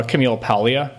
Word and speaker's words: Camille Paglia: Um, I'm gonna sound like I'm mispronouncing her name, but I'm actually pronouncing her name correctly. I promise you, uh, Camille Paglia.